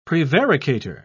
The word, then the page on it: prevaricator: British English